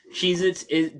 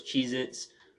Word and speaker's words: Cheez-It: Cheez-Its doesn't have a Z at the end of it. It's Cheez-It. People just pronounce it plural as Cheez-Its.